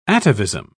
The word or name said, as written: atavism